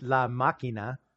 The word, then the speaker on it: La Máquina: I mean, it's La Máquina.